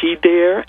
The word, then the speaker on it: Dere: It's called She Dare, and dare is a phonemic pronunciation of there, so D-E-R-E, but in the poem it also has the word D-A-R-E, dare, because I'm a wordsmith and I play with words.